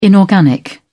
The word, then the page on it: inorganic: British English